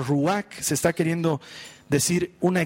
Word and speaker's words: ruach: Esta palabra Espíritu en hebreo se pronuncia ruach, y quiere decir viento.